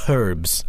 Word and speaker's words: herbs: Herbs. – Oh, you pronounce the H.